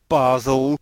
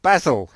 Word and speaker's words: Basel: At Baal, Basel, Baal, you know, whatever you want to pronounce it, is definitely not basil.